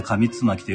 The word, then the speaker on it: 上巻: えー、これ、上官と書いて紙つまきと読むそうなんですけれども、少し前からですね、この古琴文の音読をね、させていただいております。